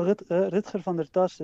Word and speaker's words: Rutger van der Tas: Oh, well, Rutger van der Tas, that will be the Dutch way.